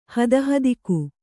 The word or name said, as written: ಹದಹದಿಕು